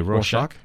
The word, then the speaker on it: Rorschach: Okay, so the 12 issues essentially is this murder mystery where someone thinks that Rorschach. – Yeah, Rorschach. – Yeah. Trying to work out that I've pronounced it right. – I think so.